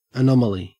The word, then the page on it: anomaly: Australian English